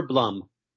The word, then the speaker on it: Blum: So the preferred pronunciation is Dave Blum, rhymes with plum.